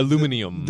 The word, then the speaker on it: aluminium: Aluminium. – The word for aluminum that everyone else pronounces other than us here in the United States.